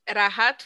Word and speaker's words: Rahat: É a música da Bad, com o cantor da Bad, que é o Ratha Fateh Ali Khan. Como é que fala o nome dele, gente? – Rahat Fateh Ali Khan. – Ratha, eu sempre falei Ratha. Não era Ratha Era Rahat.